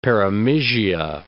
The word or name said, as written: perimysia